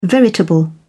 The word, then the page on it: veritable: British English